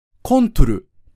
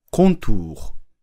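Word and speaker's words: contour: コントルと発音すると、フランス人には手の後ろに母音があるように聞こえてしまうので、コントゥー、輪郭、という全く別の単語として認識されます。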